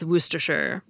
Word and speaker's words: Worcestershire: And linguists believe it may be why the middle C-E-S in Worcestershire disappeared, leaving us with Worcestershire.